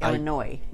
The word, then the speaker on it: Illinois: And with the States, you don't say the S's at the end. – No, I don't. – Like Illinois. – Yes. – That's how it's pronounced.